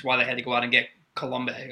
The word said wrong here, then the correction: Coulomb: Colombe? – Yeah, I kind of guessed on that. I probably should have looked it up. – Coulomb? It's Coulomb. – Coulomb. – Yep.